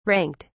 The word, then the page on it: ranked: British English